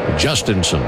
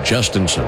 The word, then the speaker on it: Jhostynxon: J.H. O-S-T-Y-N-X-O-N. Pronounced Justinson. And I was looking at Baseball Reference. They had the pronunciation as Justinson, but we were chatting with some of the media relations members for the Pirates and said, no, Justinson. That's how he says it.